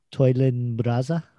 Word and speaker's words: PTOYLNBRASA: The Prime Time of Your Life, Brainwasher, Rolling and Scratching Alive medley, which I call PTOYLNBRASA. Toilin Braza, the P is silent.